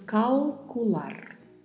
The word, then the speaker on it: calcular: E temos ao final um R com vibrato. Não se esqueça deste vibrato, treine ele porque possivelmente é uma das coisas mais difíceis de se aprender no espanhol. Então pronunciamos calcular.